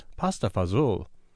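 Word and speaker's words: pasta e fagioli: Madeline says that this dish is often pronounced Pasta Fazool.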